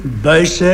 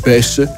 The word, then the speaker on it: Buysse: Maar op het moment dat Marcel begint te koersen, verschijnt in de krant Buysse met 2 S'en. Dat is eigenlijk een verbastering, omdat de Franse journalisten die konden Buysse niet uitspreken en die zeiden Buys.